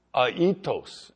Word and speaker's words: aetos: The word used for eagle here in the Greek is aetos, and it's spelled A-E-T-O-S.